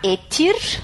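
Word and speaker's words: ættir: Que são et, et, isso, os, e, o etir é o plural de et, né? Se escreve A-E-T-T no singular e o plural etir com IR no fim.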